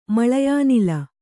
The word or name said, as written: ಮಳಯಾನಿಲ